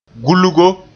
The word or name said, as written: gulgo